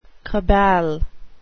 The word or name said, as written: cabal